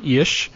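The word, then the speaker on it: Illyes: according to Gary Ish, which is actually how you say his name, I did not know that. I've been calling Ilya's forever, so it's Ish.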